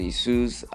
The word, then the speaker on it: Jesus: So Gabriel Jesus has been making wonderful performance, been dropping wonderful performance.